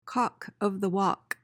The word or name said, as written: cock of the walk